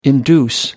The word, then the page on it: induce: American English